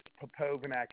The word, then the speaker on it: Popovnak: My last name is Popovnak. It's spelled P-O-P-O-V-N-A-K.